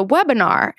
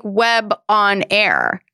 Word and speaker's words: webinar: And I was like, it's like a seminar, but it's a webinar.